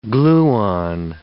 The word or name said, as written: gluon